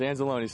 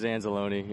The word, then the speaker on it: Anzalone: Yeah, it's Anzalone. Say it like it's pizzeria. Like it's a pizzeria. It's Italian, so it is Anzalone.